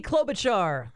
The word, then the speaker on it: Klobuchar: Senator Amy Klobuchar, is that how you pronounce it? – Yeah.